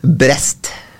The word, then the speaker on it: Brest: Brest. Det tror jeg faktisk uttales akkurat sånn som du sa nå.